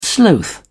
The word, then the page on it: sloth: British English